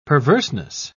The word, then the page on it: perverseness: British English